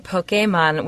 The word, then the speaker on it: Pokémon: Well, and I like, Eugene, that you're saying Pokemon, which I'm assuming is the result of that accent on the E that I've always wondered about. – Well, that's right.